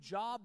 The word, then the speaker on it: Job: It's spelled J-O-B, Job, but it's Hebrew, not English, so it's Job.